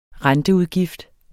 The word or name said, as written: renteudgift